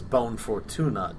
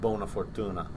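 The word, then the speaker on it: Buona Fortuna: and he says Bon Fortuna to him instead of Bona Fortuna.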